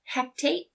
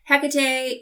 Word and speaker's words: Hecate: They invoke Hecate, but they call her Hectate. – They say it wrong, which is drives me crazy every time. But Hecate is the goddess of the crossroads.